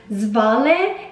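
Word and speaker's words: Svale: It's actually pronounced Zvale. It's of Norwegian origin, so this is going to create some pronunciation issues, but not here in the States.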